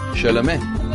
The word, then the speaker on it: Chalamet: So how do you pronounce that? – Chalamet. – Timothée Chalamet.